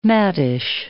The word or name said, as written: maddish